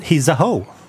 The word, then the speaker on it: Heezahoe: Vi är här för att snacka om din nya låt, Heezahoe.